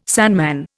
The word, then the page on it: sandman: Indian